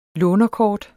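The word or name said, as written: LÅNERKORT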